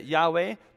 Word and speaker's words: Yahweh: The LSB translates it Yahweh, because we have good reason to believe that's the correct pronunciation.